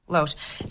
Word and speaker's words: Loesch: Loesch or Lash? I don't know Loesch.